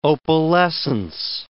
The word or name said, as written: opalescence